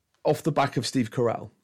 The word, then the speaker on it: Carell: Carell.